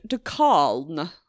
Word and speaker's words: de Calne: So the children were sent to live with a man called Richard de Calne. I'm pretty sure that's how it's pronounced.